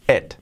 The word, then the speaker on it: it: Y la, última palabra es it, no es it, lo he dicho, la I como una E, it. I think she doesn't like it. Like it. Y fijaos la última T que la pronunciamos muy, muy poco. Está it, eh, pero muy poco, no es like it. Es muy poco, muy sutil, muy sutil.